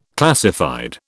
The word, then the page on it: classified: British English